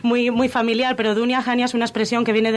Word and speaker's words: Dunya Hanya: Entonces, pues bueno, vamos a hablar de, ese proyecto cultural de Dunia, Dunia Jania Patrimonio. ¿Lo he dicho bien? Estupendamente.